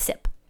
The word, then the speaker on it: CIP: I'm going to pronounce that as SIP. Or KIP. No, we're going with SIP.